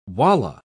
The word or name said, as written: wallah